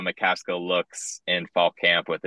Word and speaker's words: McCaskill: And also former Houston running back Alton Mescal. I may have said that wrong. – McCaskill.